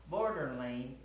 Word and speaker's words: Border Lane: Border Lane. It's spelled almost like borderline, but it's pronounced so it rhymes with Coeur d'Alene.